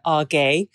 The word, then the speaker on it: AG: It's actually pronounced Software Argay.